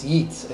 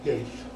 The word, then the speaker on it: Yeats: It's Yeats. It's W.B. Yeats. Huh? Yeats.